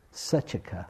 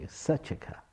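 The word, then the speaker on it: Saccaka: It's called the Greater Discourse to Sakaka. I don't know how to say the name right. Sachaka, thank you. Sachaka. Double C, Sachaka.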